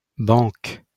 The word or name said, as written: banque